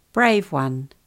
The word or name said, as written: brave one